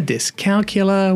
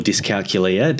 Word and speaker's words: dyscalculia: So, how should we be pronouncing this? Dyscalculia, dyscalculia, what? – It is dyscalculia or dyscalculia, depending on how you say it. The pronunciation varies, I find. Yeah. I've settled on dyscalculia. – Me too. – Do you know why? I just think it sounds cooler. – C-A-L-C-U.